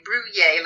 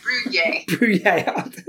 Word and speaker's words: Bruhier: No, it's pronounced Bruhier, like you're having a brew and your team is winning. Yay, Bruhier.